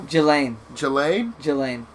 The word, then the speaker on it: Ghislaine: Ghislaine.